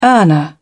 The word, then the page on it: earner: British English